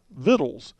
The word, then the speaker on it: victuals: Look at this word and memorize it, because this word here is spelled V-I-C-T-U-A-L-S. It looks like victuals, but it's actually pronounced vittles.